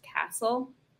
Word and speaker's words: Cassell: Her last name is spelled like Cassell, but I'm pretty sure it's pronounced Castle.